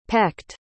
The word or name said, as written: pecked